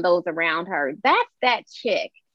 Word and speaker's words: Chic: Like, Korea, it's C-H-I-C is pronounced chic. And I said, well, in French, maybe it is, but here is chick.